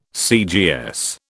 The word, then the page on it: CGS: British English